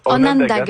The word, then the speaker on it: Onanga: Vos vedettes, ce sont le Empress of Ireland puis le sous-marin Onanga, je ne sais pas si c'est comme ça. Est-ce que c'est comme ça qu'on le prononce? – Onanga, ça veut dire peuple de la colline, effectivement. Oui, tout à fait.